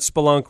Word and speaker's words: spelunk: Just remember that spelunk rhymes with kerplunk.